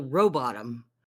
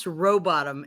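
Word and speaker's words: Rowbotham: I was just going to tell you that it's pronounced Samuel Robottom. And I was looking to find the funny story because somebody claimed to have known him. Go ahead. – Oh, I was going to say, is the H silent? – He said, yeah, it's pronounced Robottom.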